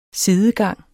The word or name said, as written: sidegang